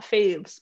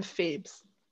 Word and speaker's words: Pheebs: And you can follow me at Fat Phoebes, which is spelled Fat and Phoebes. Do I have to spell my name too? Okay. – No. – P-H-E-E-B-S.